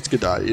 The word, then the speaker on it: Gadot: I have never said Gadot. – I used to always say Gadot, but I've heard people say Gadot, and it made me think, maybe I'm saying it wrong? – No, I've heard them say Gadot, and I'm like, nah, it's Gadot. You're not telling me any different.